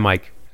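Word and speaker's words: Myke: I'm Mike.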